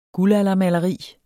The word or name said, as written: guldaldermaleri